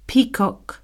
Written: peacock

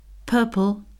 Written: purple

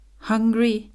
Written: hungry

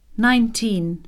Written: nineteen

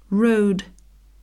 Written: road

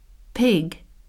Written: pig

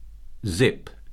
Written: zip

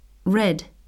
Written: red

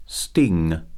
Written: sting

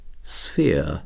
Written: sphere

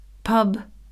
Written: pub

